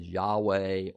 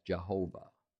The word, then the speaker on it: Yahweh: It is the Hebrew word denoting the name of God, which we often pronounce as Yahweh or as Jehovah.